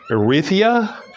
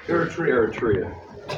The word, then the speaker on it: Eritrea: I'm going to say Erythia? – Eritrea.